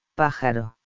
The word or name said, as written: pajaro